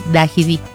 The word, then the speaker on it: Dajidí: O localidad de Actopan, que es a mí me cuesta un poquito pronunciar, Viajidí, es Dajidí.